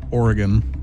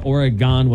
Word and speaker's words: Oregon: But I found out there is an Oregon, Wisconsin, and they pronounce it Oregon.